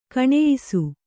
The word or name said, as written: ಗುಣಿಯಿಸು